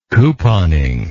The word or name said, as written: couponing